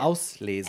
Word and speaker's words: Auslese: Auslese.